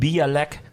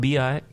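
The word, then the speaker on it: Białek: Niet Bialek, maar Biawek, ja.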